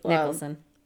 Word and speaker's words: Nicholson: Scott Nelson used... – Nicholson. – Nelson? – Nicholson. – Nicholson. I don't see how you get that part. – If you see Jack Nicholson's name, it's spelled the same way. Nicholson.